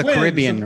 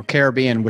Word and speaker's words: Caribbean: Yeah, it's down in the Caribbean or Caribbean, whichever way you want to pronounce it.